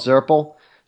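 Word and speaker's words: Zurple: I use a system called Zirple. So Zirple is a system I use to manage my buyers. – Okay, Zirple. How do you spell that? Z-U-R, like purple, but with a yeah.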